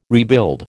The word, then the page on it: rebuild: American English